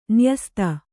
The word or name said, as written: ನ್ಯಸ್ತ